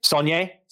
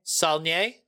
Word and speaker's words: Saulnier: Now, how do we say his last name? – Saulnier. – Saulnier. – Saulnier. Yeah.